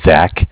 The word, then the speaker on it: Zach: Well, my name is Zach, spelled Z-A-C-H.